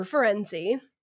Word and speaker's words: Firenze: It was like a a here are a bunch of characters that you've been pronouncing wrong the whole time, and it described Firenze's name as sounding like frenzy.